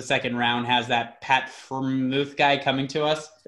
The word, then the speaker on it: Freiermuth: Freiermuth? You know? – Freiermuth.